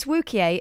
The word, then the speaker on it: Wookiee: Also, it's Wookiee, as rhymes with Bookiee, not rhyming with spooky.